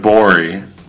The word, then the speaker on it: Gborie: If you want to hear more from David Borey, please go Google his name. David G-B-O-R-I-E. The G is silent on Twitter.